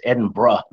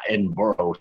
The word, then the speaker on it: Edinburgh: She always corrects me and says it's Edinburgh, not Edinburgh.